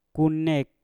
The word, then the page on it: ku nek: Mpur